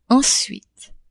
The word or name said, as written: ensuite